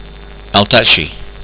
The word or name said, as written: altakchi